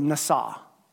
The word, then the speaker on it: nasa: The Hebrew word here is the word nasa. Now, nasa, it's spelled N-A-S-A, NASA, right?